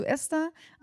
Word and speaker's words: Esther: Es ist mit einem kurzen E vorne. – Mit einem quasi Esther. – Esther. – Und nicht Esther. – Esther. – Es geht um die Betonung.